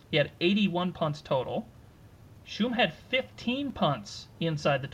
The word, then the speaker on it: Schum: Jacob Schum, is that what we said his last name is? – Yes. – Correct pronunciation on last name.